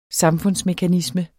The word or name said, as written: samfundsmekanisme